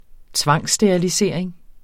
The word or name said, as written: tvangssterilisering